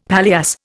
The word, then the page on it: paillasse: Indian